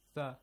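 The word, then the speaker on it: Tha (ث): Do not, okay, for example, firmly do not pronounce the sound. – Tha. No. Tha, simply. Tha, softly. Lightly. Tha, lightly.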